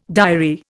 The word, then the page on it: diary: Indian